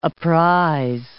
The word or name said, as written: apprize